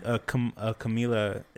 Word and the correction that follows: Kamala: is it? – Kamala Harris. – Is it Kamala Harris? – Yeah. – Damn, I've been mispronouncing her name the entire time.